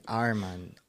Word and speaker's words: RMAND: Simplemente como que arreglaron cómo se escribe, o sea, para que no se escriba A-R-M-A-N-D, podría quitar la A y que sea la R como en inglés, como R. Armand.